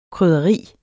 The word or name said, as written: krydderi